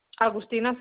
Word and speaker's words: Agustina: Agustina Febreros.